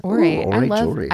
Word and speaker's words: Aurate: It's weird because it's not spelled like orate. It's A U R A T E.